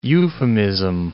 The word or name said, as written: euphemism